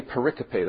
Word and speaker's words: pericope: it's a word there that is pericope. Some would think that I've misspelled periscope, but it's actually a word which is used as technically pericope, that's the way it's pronounced.